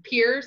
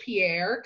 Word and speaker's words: Pierre: Many people will probably pronounce it Pierre because that's the way it's spelled and how I would normally say it, but my dad corrected me today. So I'm going to take my dad would know. Pier, like Navy Pier, or we are walking off a pier.